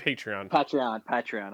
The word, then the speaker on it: Patreon: Is that how you pronounce those? Like the podcast that people charge for? – Oh, Patreon. – Patreon. – I've only seen it written. I've never actually heard it said out loud, so I wasn't sure how to pronounce it.